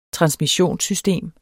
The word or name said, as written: transmissionssystem